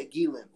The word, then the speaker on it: Ghislaine: That's how you say her name? – Yeah, Ghislaine. Well, on the docs, that's how they said it.